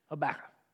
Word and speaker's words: Habakkuk: The way I learned to pronounce Habakkuk is just say tobacco and put an H on top. Habakkuk.